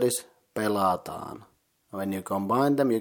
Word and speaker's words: pelataan: The second word pelataan is consisting of three syllables, pe, la, and taan. The first syllable pe is the same as in pension, so pe. The second syllable la is pronounced like in the game's name La Granja, just the beginning la, and that's it.